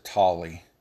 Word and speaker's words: Talley: One of them was a police officer, Eric Talley, T-A-L-L-E-Y. I think they pronounce it Tolly.